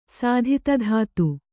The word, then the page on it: ಸಾಧಿತಧಾತು: kannada